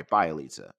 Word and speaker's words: Bjelica: Um, Bielsa, Bjelica, I'm sorry, Bjelica. I always mispronounce his name.